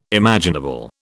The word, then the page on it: imaginable: British English